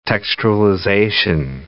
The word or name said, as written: textualization